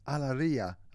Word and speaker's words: Alegria: And we started off with Alegria, apparently is how it's pronounced, and not how I mashed it up before, by Elia and Elizabeth, a singing duo from Colombia.